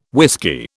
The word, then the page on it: whisky: British English